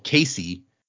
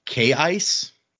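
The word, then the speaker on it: Kaise: who we called Casey, uh, it's written K A I C E. Apparently, the pronunciation is actually K ice, which is weird, and I refuse to say that.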